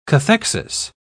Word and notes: cathexis: British English

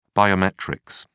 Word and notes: biometrics: British English

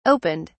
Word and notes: opened: British English

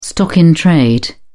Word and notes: stock-in-trade: British English

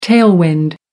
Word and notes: tailwind: American English

